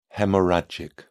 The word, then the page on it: haemorrhagic: British English